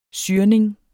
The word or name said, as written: syrning